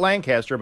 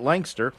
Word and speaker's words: Lancaster: And if you go up to Lancaster, not Lancaster, but Lancaster. – Yeah, I know. It's and that's the correct way to say it.